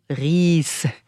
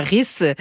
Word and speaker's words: Ries: L'ensemble Nash jouait le sextuor pour piano opus 142 de Ferdinand Ries. Et non pas Ries, j'ai un connaisseur de la langue allemande, la PO, il connaissait beaucoup l'allemand. Merci d'avoir corrigé ma prononciation, PO.